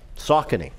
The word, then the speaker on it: Saucony: You know, I didn't know how that company's name was pronounced. – Saucony. – Yeah. I thought it was Saucony. – Saucony? – Yeah, that's what I thought. I've been saying it wrong for years.